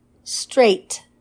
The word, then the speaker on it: straight: Straight. It rhymes with weight, date, and the number eight.